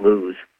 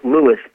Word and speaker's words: Lewes: it is pronounced Lewis, L-E-W-E-S, not lose.